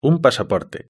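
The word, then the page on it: un pasaporte: Spain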